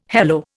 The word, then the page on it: hello: Indian